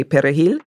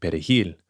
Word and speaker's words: perejil: how they pronounced the word Perejil, the Spanish word for parsley. – Because Haitians, in Creole, we use a guttural R, and Latinos roll their R.